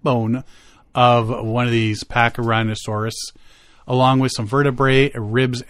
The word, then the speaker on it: Pachyrhinosaurus: The Pipestone Creek bone bed is one of the richest deposits of Pachyrhinosaurus. It's got to be rhinosaurus, not rhinoceros, because it's spelled different. – Yeah, I think it's rhinosaurus. – Pachyrhinosaurus.